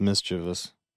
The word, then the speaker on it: mischievous: Yeah, well, I don't even know why I add extra syllables in there. It's not. It's mischievous. I'm a moron. Just realized. – Would you say mischievous? – I said mischievous. There's mischievous. No E-S. Like, it's just us.